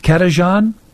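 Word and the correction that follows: Cajetan: Kajetan. – Kajetan.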